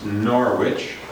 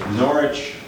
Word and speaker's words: Norwich: She was a woman who lived in the late 1300s in England, in the city we Americans commonly pronounce Norwich, but in England no one would say Norwich. They would say Norwich, or they would say Norwich.